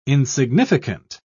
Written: insignificant

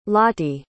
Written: Lottie

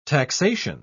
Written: taxation

